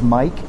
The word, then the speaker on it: Mike: My email address, if you have any questions or just want to get in touch with me, is Mike, M-I-K-E, at...